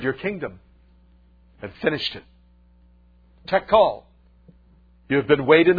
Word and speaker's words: Tekel: The words are pronounced Mene, Mene, Tekel.